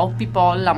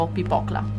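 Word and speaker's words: Hoppípolla: La canzone si chiama Valtari, ma io non so come si pronunci, perché è islandese e come sempre sarà difficile, come quell'altra che si chiamava Oppipolla, ma in realtà si pronunciava Oppipokla.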